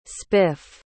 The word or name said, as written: spif